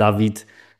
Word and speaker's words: David: Also ich bin David Pszybylski, sehr komplizierter Nachname, deswegen nennen mich alle David. David ist so die richtige Aussprache, du sagst es ja auch.